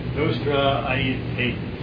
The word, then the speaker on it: Nostra Aetate: A-E-T-A-T-E, that's a name. Nostra Aetate.